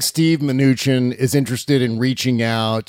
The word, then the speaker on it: Mnuchin: No, it's not Steve Mnuchin. Steve Mnuchin. That's how you pronounce the guy's name.